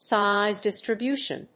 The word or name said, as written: size distribution